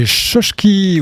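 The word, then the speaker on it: tchotchke: C'était Shoshki. Oui, on ne prononce pas le premier T.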